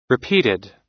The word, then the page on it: repeated: American English